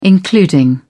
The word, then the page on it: including: British English